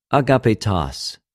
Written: ἀγαπητος

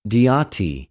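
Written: διοτι

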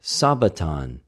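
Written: σαββατον

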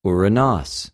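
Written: οὐρανος

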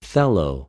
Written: θελω

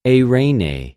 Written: εἰρηνη

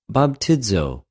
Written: βαπτιζω

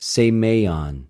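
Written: σημειον

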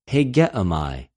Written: ἡγεομαι

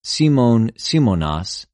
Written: Σιμων, Σιμωνος

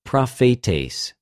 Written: προφητης

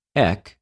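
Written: ἐκ

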